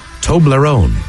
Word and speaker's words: Toblerone: Toblerone.